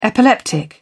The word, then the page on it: epileptic: British English